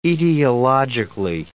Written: etiologically

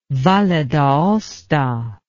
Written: Valle d'Aosta

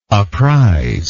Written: apprizer

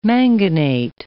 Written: manganate

